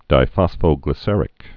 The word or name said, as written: diphosphoglyceric acid